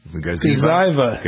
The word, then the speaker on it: Gazyva: how do you pronounce it? G-A-Z-Y-V-A, Gaziwa? – Gaziva. – Gaziva. Okay.